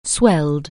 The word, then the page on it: swell: anglais